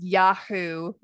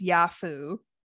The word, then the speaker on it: Yahoo: Or another common one is Yahoo versus Yafu. I hear that all the time. – Yahoo. – Yeah, Yahoo versus Yahoo.